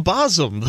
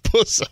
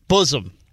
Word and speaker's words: bosom: Don't turn the tables here, pal. It's not Buzzum. It's not. Can you say Buzzum? – Buzzum. – Thank you. Say it again. – Bosom. – Say it like you mean it. – Bosom. – There you go.